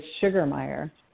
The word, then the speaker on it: Sugermeye: Yes, it is Sugermeye.